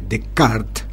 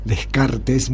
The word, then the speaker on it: Descartes: 100 años antes de Descartes, como bien pronuncia Feynman, yo lo había pronunciado Descartes, muy castellanizado.